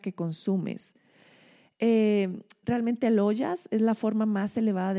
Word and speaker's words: ojas: Luego tenemos a ollas. No sé si han escuchado ollas, se escribe O-J-A-S.